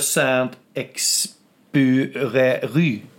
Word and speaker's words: Saint-Exupéry: Antoine de Saint-Exupéry.